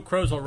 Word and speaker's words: Krozel: Friday, May 28th, by Joe Krozel, rhymes with puzzle.